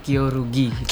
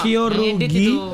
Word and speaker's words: Kyorugi: Kalau Dava, ya ini ada juara 3 Kyoguri Junior M5. – Itu bacanya Kyorugi. – Oh, kembali. Ya? Oh, ini typo nih yang nulis nih. Kyorugi.